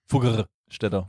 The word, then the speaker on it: Fuggerstädter: bei den Wo, wobei, wobei, mir ja gesagt wurde, ähm, auch quasi kleines Feedback, ich habe das nicht richtig ausgesprochen, weil man muss das R mehr rollen. Fuggerstädter.